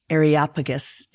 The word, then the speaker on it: Areopagus: And then a couple of chapters later, he's with these cheeky Greeks and the, I always have a hard time pronouncing, Areopagus. – Oh, wow. – That's how it's pronounced.